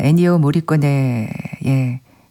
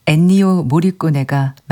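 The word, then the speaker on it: 엔니오 모리코네: 이민하님, 일요일날 제가 애니오 모리꼬네의 작품을 전해드렸는데 제가 애니꼬 모리꼬네라고 발음을 했답니다. 더위를 먹은 걸로. 죄송합니다. 이민하님, 애니오 모리꼬네가 맞습니다.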